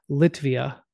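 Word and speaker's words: Lietuva: I believe the way the Lithuanians pronounce it is Litvia.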